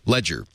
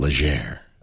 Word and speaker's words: Legere: Ledger, pardon me, I mispronounced it. It's not Legere.